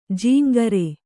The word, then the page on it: ಝಂಕಾರ: jhaŋkāra